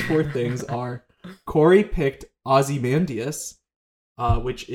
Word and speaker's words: Ozymandias: it's like a first name, but it's like a light on the Y, so it's an Ozymandias. – Ozymandias.